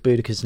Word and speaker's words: Boudicca: Jennifer Wien asks why the change in pronunciation, and I gather this is with Boudicca's name. – Mm. When I was a child, long, long ago, she was always Boadicea. We think Boudicca is probably the way she would have pronounced it.